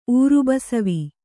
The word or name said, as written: ಊರುಬಸವಿ